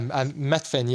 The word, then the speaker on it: Methven: Yeah, Methven, yeah.